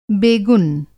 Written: बेगुन

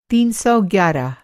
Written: तीन सौ ग्यारह

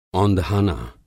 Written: औंधाना

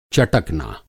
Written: चटकना

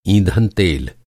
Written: ईंधन

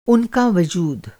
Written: वजूद

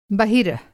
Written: बहिरः

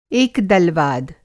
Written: एकदलवाद

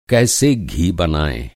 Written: घी

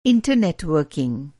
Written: इंटर

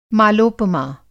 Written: मालोपमा

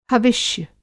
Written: हविष्य

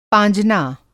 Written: पाँजना

